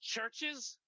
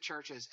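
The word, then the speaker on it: Chvrches: Isn't it pronounced churches? But yes, it's spelled with a V. – Yes, you pronounce it churches, and that record is so good.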